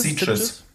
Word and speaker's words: Sitges: Wie spricht man das aus? Sitges? – Sitges.